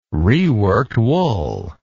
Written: reworked wool

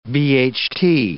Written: BHT

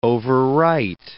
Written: overwrite

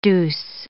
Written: deuce

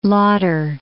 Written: Lauder

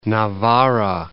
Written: Navarra

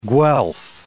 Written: Guelph

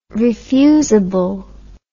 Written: refusable

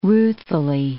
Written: ruthfully

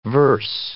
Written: verse

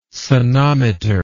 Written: sonometer

